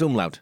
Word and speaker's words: umlaut: How do you say it's was it umlaut? – Yeah, it's umlaut.